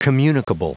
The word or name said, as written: communicable